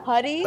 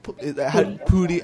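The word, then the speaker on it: Pudi: Can I also say that my mom, whenever I'm watching Community, she's like convinced that like Danny Putty like isn't Putty? – Like Pootie.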